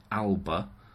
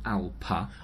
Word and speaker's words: Alba: The word, as I've been saying it, alba, and as it's spelt, is not pronounced as written, as with most Gaelic words. Minimally, exchange the B for a P, so alpa.